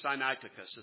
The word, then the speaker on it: Sinaiticus: and Sinaiticus, or Sinaiticus, as some people pronounce it.